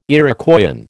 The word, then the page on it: Iroquoian: American English